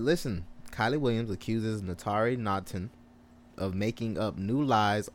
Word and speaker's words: Naughton: Moving on to 3LW, former 3LW member Kylie Williams accuses Natari Nottington. Did I say her name right? – I thought it was Natari Naughton. – Natari Naughton. Okay, yeah.